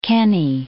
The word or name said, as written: Kenny